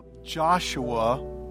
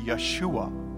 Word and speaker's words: Yeshua: But the Hebrews don't say the hard J, Joshua. They say a Y. They say Yeshua. That's how they would say it in Hebrew.